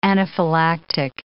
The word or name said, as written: anaphylactic